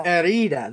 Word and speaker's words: Herida: How do you pronounce that? Errada? – Herida. – There you go. Sorry about that. All you people, I'm not hashing it up. – Just think of ear and say Herida.